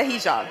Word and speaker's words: hijab: Yes, the hijab.